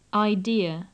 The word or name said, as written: idea